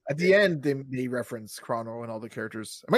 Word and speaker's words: Chrono: Yeah, Chrono. I say it like with a long O, like a crow-no. – Corona. Okay, I'm saying Chrono.